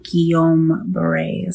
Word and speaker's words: Guillain-Barré: And I just watched my videos that I put up and I realized that I totally mispronounced what's going on with me. It's called Guillain-Barré syndrome.